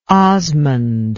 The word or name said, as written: osmund